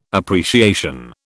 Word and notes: appreciation: British English

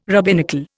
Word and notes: rabbinical: Indian

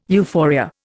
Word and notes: euphoria: Indian

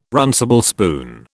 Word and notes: runcible spoon: British English

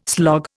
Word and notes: slog: Indian

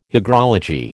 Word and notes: hygrology: American English

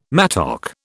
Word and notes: mattock: British English

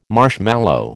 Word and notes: marshmallow: American English

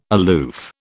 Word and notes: aloof: British English

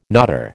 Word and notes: knotter: American English

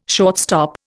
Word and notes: shortstop: Indian

